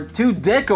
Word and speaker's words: Tudyk: It's Alan Tudyk. – Or Tudyk, or how you say it? – That's Tudyk.